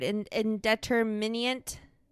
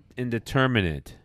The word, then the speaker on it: indeterminate: Help me with this word. Indeterminant. Is that the word I'm saying? – Hold on. Indeterminate.